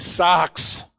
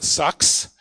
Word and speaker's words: sarx: The word John uses here for flesh in the Greeks is sarx. Sarx. It's a harsh word. It's not sucks. Sarx. In English, you would spell it S-A-R-X. Sarx.